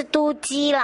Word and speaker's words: 都基: 不过倒是啊，这个都市基础工程组哦，有时候我们在节目里面简称都基都基，然后人家就说你发音标准一点，真的是都基啦，好、哦，不是我在说妒忌啦，哦，我这个一定要让那个副座好好的解释给大家听，都基组哦，其实呢是三个组的合并啦。